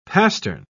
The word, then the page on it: pastern: British English